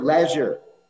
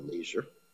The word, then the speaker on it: leisure: Like even here, Prabhupada says, There's no material leisure or leisure, different ways of pronouncing it, for a person in Krishna consciousness.